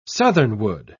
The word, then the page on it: southernwood: British English